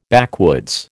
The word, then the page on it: backwoods: American English